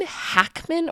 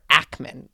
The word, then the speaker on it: Hackman: um, side note, is it Gene Hackman or Ackman? – Like, is that Hack. a silent H? – No, I, b- I believe it's a full H.